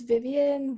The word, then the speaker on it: Vivienne: But my socials, it's Vivian Nguyen, but my name is spelled a little different. So it's V-I-V-I-E-N-N-E and then H-U-Y-N-H.